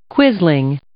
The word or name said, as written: quisling